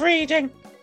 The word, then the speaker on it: Reading: It's in the town of Reading. It, uh, reading. He's dyslexic, isn't it? The, the, the, Judy, this is pronounced Reading.